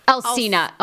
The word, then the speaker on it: Alsina: Alsina. Oh, Lord. – Alsina sounds like Encino, but okay, August Alsina.